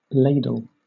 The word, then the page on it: ladle: British English